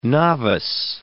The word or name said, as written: novice